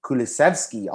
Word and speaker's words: Kulusevski: They bring in Bentancur from Juventus and Kulusevski, also from Juventus.